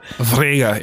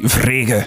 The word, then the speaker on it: Wrege: Het wordt geschreven, help mij denken, W-R-E-G-E. – W-R-E-G-E? – Ja, hoe zou je die naam uitspreken? – Maar dat lijkt mij gelijk dat er zo een of ander een Duits is dat geëmigreerd is geweest. Vrege, ja, oké.